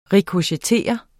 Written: rikochettere

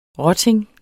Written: rotting

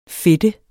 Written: fedte

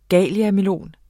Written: galiamelon

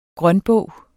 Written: grønbog

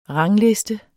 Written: rangliste

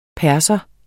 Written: Persere

